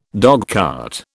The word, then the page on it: dogcart: British English